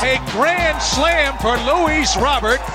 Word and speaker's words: Luis Robert: Yeah, in Cuba, siempre se pronuncia Luis Robert.